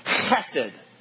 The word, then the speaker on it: chesed: You got to spit it. Chesed. Chesed love.